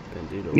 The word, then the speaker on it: Bandido: How do you say this guy's name? Bandido. Did I say that right? – Bandido. Yeah.